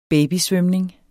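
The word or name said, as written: babysvømning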